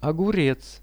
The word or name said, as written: ogurez